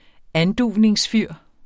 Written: anduvningsfyr

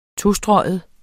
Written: tostreget

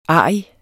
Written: ARRIGSTE

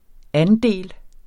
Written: andel

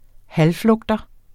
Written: halvflugter

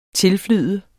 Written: tilflyde

